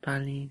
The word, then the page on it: pali: Lwaitel